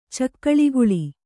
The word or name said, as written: ಚಕ್ಕಳಿಗುಳಿ